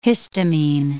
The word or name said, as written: histamine